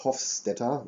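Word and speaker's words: Hofstadter: Also Richard, Richard, Richard Hofstetter, so spricht man richtig aus.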